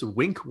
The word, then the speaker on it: Winc: We've talked about Wink before. It's spelled W-I-N-C, and it makes it easy to discover great wine. And that's because Wink's wine experts select wines matched to your taste, personalized just for you, and shipped right to your door.